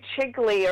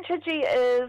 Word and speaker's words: Chidgey: This year, every day someone comes in and says, has the new Chigley arrived? Which is an understandable mispronunciation of Catherine's name, but it's finally here, and I'm pretty sure most mothers got this for Mother's Day yesterday. So, but Catherine Chidgey is one of New Zealand's most awarded currently writing authors.